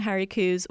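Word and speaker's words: Hairy coos: And of course I saw some Harry Coos, which, if you don't know, is the proper way to say the Highland cows, or the Scottish cows, the ones you think of, the brown ones usually with long hair. That's how you pronounce it correctly, or at least as correctly as I can as someone with a Canadian accent.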